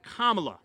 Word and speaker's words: Kamala: California Senator is Kamala, like comma.